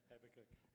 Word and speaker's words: Habakkuk: And then just finally, Habakkuk. Is that how you say it? – Habakkuk.